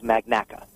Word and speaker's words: Magnacca: And um, my last name is Magnacca.